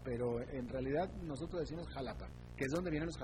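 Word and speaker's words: Xalapa: Porque se escribe con X, es Jalapa.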